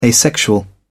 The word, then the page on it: asexual: British English